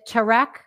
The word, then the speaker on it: Tarek: I remember the first time she got divorced, she became popular because she was on like a home show with Tarek. Is that how you pronounce his name? – Yeah. – Yeah.